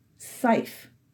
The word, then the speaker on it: Seif: It is pronounced Seif, with a long I sound. The E is silent.